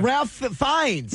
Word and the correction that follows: Ralph Fiennes: Ralph, it's spelled like Ralph, though. – It is. – Yeah, yes, it is. – What is it called? – It's Ray Fiennes.